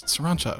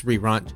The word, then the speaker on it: Sriracha: How do you pronounce it? – It's Sriracha. – Sriracha. Sri. Because the actual sauce is pronounced Sriracha.